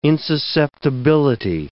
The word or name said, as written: insusceptibility